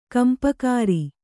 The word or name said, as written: ಗುಂಪುಗಾರ